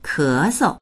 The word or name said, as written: ke2sou